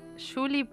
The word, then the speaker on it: Giuli: La dirección es Julie Potenza-bajo. Julie se escribe G-I-U-L-I.